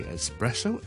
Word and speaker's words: espresso: espresso, E S P R E S S O，或者有啲人咧就系读咧 espresso, espresso 喺英文啊，唔系意大利文，喺英文通常读一次 espresso, espresso，读多次 espresso or espresso。咁咧，但系咧，好多香港人就会读成，有时甚至唔系香港人，好多其实英文国家人咧都会将读成咧系 espresso 嘅，espresso 就加咗个 K 音喺度嘅。咁呢个咧就唔可以叫做一个正确嘅读法，咁啊大家留意啦。